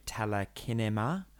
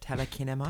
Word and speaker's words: Telekinema: The film exhibition space, the telekinema, how do you say it? – That's how you say it. – Telekinema.